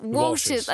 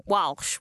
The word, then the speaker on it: Walsh: Walshers or Wash? Could you spell it? – Walshers, as in like Walshers. The surname Walsh. Walsh, W-A-L-S-H.